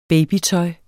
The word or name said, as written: babytøj